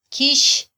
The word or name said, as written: kyix